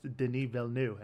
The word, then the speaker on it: Denis Villeneuve: Directed by Denis Villeneuve, who I incorrectly was calling him Denis Villeneuve for like months. And I have a friend, Chance, who was like, that's not how you pronounce his name at all. It's pronounced Denis Villeneuve.